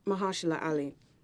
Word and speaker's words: Mahershala Ali: Is it Mahershala Ali? – Mahershala Ali.